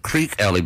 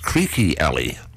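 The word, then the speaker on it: Creeque Alley: And I used to pronounce it Creek Alley, but then it was our dear colleague Lacey, who's American, who corrected me and said, no, it's pronounced Creaky Alley.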